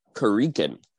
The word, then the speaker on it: Khruangbin: I think it's... – Khruangbin? – Khruangbin. – Oh, that's much simpler. – Well, it's only three syllables.